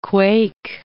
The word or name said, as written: quake